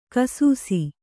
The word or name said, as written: ಘೈಸಾಸ